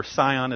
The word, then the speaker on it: Zion: So in historical terms, Mount Zion, or Sion as it's sometimes pronounced, is the central part of Jerusalem.